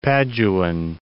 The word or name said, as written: Paduan